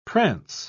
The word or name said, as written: prance